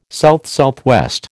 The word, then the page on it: SSW: American English